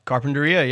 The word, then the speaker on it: Carpinteria: My aunt and cousin used to live in Carpinteria. – Oh, yeah. – Is that how you say it? – Carpinteria, yeah, you got it. You nailed it.